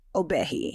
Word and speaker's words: Obehi: I feel like when I'm in serious mode, like work mode, it's Obehi, you know?